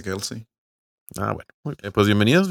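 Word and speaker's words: Kelce: que lo dijo Kels. – Kels, no, Kelsey.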